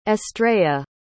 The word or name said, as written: estrella